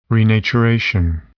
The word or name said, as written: renaturation